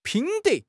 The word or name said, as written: 品德